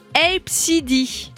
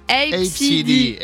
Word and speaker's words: ABCDE: Eh ben, écoutez, ça se prononce A, B, C, D. – Epsidy, Epsidy. – Alors c'est mignon, dit comme ça, Epsidy, ça peut être sympa, mais ABCDE.